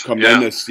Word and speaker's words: Komnenos: the Manuel Komnenos, I think that's how you pronounce it, Komnenos. – Komnenos, yeah, who is the last emperor of the Byzantine Empire, and it's over.